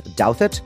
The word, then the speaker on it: Douthat: Our Gentile of the Week will be Ross Douthat. That's how you pronounce it. People always wonder.